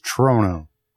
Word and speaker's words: Trono: It's Trono.